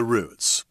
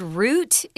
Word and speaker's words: route: Those are all the routes. – We pronounce this word route in Taiwan, but you need to know that it's also pronounced route.